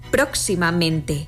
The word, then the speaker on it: próximamente: Así lo vemos en próximamente, donde se pronuncian con más fuerza las sílabas pro y men.